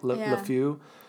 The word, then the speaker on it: LeFou: LeFou, it's LeFou, right? LeFou, is that how you pronounce it? – LeFou.